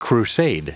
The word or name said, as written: crusade